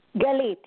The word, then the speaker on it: Galit: Galit, yes.